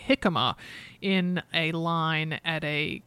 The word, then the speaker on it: jicama: And people were like, "It's jicama."